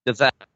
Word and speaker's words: Dozzell: Is, it you pronounce his name Andre Dozzell? Uh, yeah. Dizel.